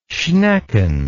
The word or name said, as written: schnecken